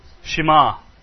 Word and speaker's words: Shema: Some people say Shema. It's a Hebrew word which means hear. Shema. The stress is on the ma, isn't it? Yeah, Shema.